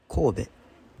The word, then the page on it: 神戸: Kōbe; [koꜜː.be]; Japanese